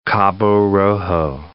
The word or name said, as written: Cabo Rojo